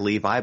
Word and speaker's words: Levi: Last name spelled L-E-V-I. It looks like Levi, but it's pronounced Levi.